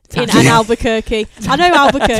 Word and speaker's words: Albuquerque: And Albuquerque.